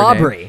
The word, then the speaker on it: Aubrey: Aubrey. – Name. – Yeah. Right. – Because like somebody in her ear or whatever, somebody held up a sign like, being like, don't say Audrey. – Pronounce the B.